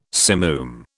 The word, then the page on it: simoom: British English